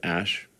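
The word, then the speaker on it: Æ: And then the AE is like pronounced Ash.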